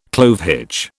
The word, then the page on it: clove hitch: British English